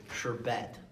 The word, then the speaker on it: sherbet: Oh, I don't like it when you say sherbet. – Sherbet? Because that's how it's pronounced and how it's spelled. – It doesn't matter. – It's S-H-E-R-B-E-T. There's no second R.